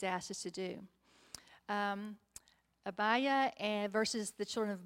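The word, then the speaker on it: Abijah: And Abiah, and that's the way to pronounce it in the Bible, so in the program, Abiah.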